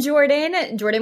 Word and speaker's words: Geordian: I'm Jordan with the G.